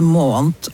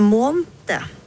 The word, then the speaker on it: måned: Jeg sier både månt og annet.